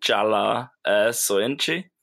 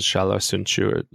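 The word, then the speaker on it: Çağlar Söyüncü: At least I was on Transfermarkt earlier and listened to the pronunciation. I think it was Shala Sunchu or something like that. Yeah, because that kind of G, you know, in Turkish, you don't say it like Gundogan. You don't say the second G.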